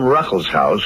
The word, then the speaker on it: Ruckelshaus: Here with me today in the studio is the Honorable William Ruckelshaus, who is administrator of the EPA, the Environmental Protection Agency.